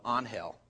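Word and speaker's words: Ángel: And his family pronounced it the proper Spanish pronunciation of Angel. And we would try to say Angel, and everybody else would say Angel because it's spelled Angel.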